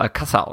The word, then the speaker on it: Alcácer: Äh, ich, ich, muss mich jetzt echt umgewöhnen, weil ich gerade erst erfahren habe, dass der Alcázar ausgesprochen wird.